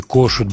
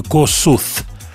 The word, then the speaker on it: Kossuth: Э, вот говоря о времени, Кошут были такие сигареты. Пишется K-O-S-S-U-T-H. То есть по английскому правописанию «косуth». Вот. Э, и называли их, я помню, косоч Такие сигареты были зеленого цвета в продаже.